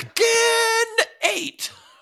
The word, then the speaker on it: Tekken 8: Tekken 8. A new challenger appears. – That's the correct pronunciation. – I mean, that's just how the crazy, like, ridiculous Japanese announcer says it.